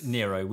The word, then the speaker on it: Nero: Like, rhymes with Piro. – Yes. – Nero.